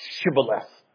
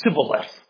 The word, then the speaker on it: Shibboleth: Because for some strange reason, the Ephraimites could not pronounce Shibboleth. They pronounced it Sibboleth. They couldn't get the H.